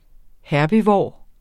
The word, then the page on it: herbivor: ˈhæɐ̯biˌvɒˀ; ˈhæɐ̯biˌvoˀɐ̯